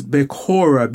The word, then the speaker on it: Bekhora: The word for firstborn is Bekor, B-E-K-H-O-R, which is very similar. And the word for birthright, which is Bekhora, B-E-K-H-O-R-A. And they sound very similar, and they are from the same derivative, the same root in the Hebrew language.